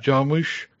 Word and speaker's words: Jarmusch: Is that how you pronounce his name? – Jarmusch. – Yeah. That's the one.